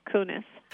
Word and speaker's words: Koones: So I've mispronounced your name all the way through the interview, and it's spelled K-O-O-N-E-S. Is that correct? That's right. And it's Sherry Kunis.